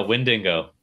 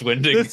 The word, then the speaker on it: Wendigo: No, Wendigo. When they announce him, and his name's Wendigo, you're gonna be so mad at yourself for saying it wrong all these years. So just start now. Start now while you're ahead. It's Wendigo.